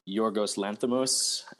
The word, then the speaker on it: Yorgos Lanthimos: The Killing of a Sacred Deer is the new film from Yorgos Lanthimos, and I'll have to ask my Greek roommate tomorrow how badly I butchered that name, but sounds right to me. – It was close enough, but he did the Lobster, which came out 2 years ago, and this was a much more sinister version.